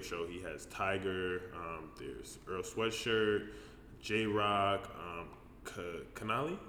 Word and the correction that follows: Kalani: is that how you pronounce his name? – Kalani, yeah.